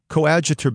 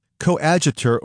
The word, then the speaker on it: coadjutor: Within a few years, Bishop Valerius saw to it that Augustine was consecrated coadjutor bishop. Coadjutor, or sometimes pronounced coadjutor bishop, is the assistant bishop with the right of succession once the principal bishop died or retired.